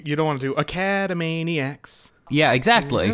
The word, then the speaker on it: Academaniacs: I mean, it's either academaniacs or yeah, it would be academaniacs.